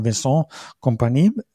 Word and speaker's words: Vincent Kompany: eigentlich heißt er ja Vincent Kompany, also etwas französischer ausgesprochen, wie das damals war, als er zum HSV gekommen ist.